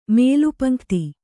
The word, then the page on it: ಮೇಲುಪಂಕ್ತಿ: mēlu paṇ'kti; Kannada